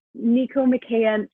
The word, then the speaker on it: Nicomachean: but Neomachianism ethics. – Yeah. – So Neomachaic? – Nicomachean. I've heard it pronounced like six times, and it's all pronounced differently.